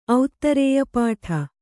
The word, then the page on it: ಔತ್ತರೇಯಪಾಠ: auttarēya pāṭha